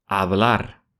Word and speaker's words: hablar: Пишется глагол «hablar» через букву b в середине, «hablar», но в испанском мире чаще всего глагол «hablar» произносится как бы через букву мягкую, что-то среднее между «б» и «в».